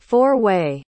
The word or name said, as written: four-way